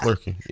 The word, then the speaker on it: Flerken: Flerken. I'm probably saying that wrong. Flerken? – Yeah. Flerken, yeah.